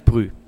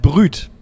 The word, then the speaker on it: brut: Ja, pass auf, es heißt nicht Brü. – Oh. – Nein, es heißt Brüt.